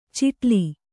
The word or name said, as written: ಚಿಟ್ಲಿ